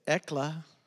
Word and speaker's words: ecclesia: Say, ecclesia. That's how you pronounce it, ecclesia.